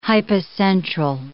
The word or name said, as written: hypocentral